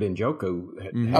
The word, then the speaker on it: Njoku: And then God, what happened in Njoku, or I don't know if I'm saying it right, David Njoku. – I believe that's right.